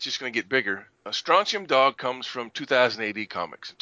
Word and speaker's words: Strontium Dog: Now let's talk about some of the other games that you casually mentioned in the interview, starting with your new one, Strontium Dog. – Oh, I said that correctly. – Strontium Dog, yeah.